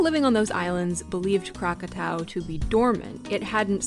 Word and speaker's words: Krakatau: On Sunday, August 26, 1883, in what is now Indonesia, a volcano known as Krakatau, which has been somewhat westernized or mispronounced as Krakatoa, began to erupt.